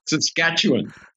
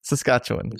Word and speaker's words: Saskatchewan: Saskatchewan. – Saskatchewan.